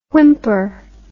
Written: Whymper

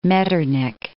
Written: Metternich

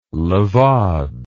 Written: levade

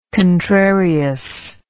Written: contrariousness

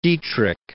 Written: Dietrich

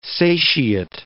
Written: satiate

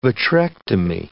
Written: vitrectomy

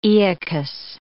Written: Aeacus

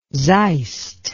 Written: zeist

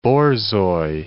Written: borzoi

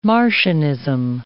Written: Marcionism

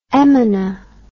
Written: emina

